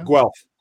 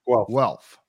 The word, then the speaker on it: Guelph: Guelph, am I pronouncing that right? Guelph, Ontario? – Guelph. – Guelph, all right.